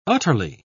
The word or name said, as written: utterly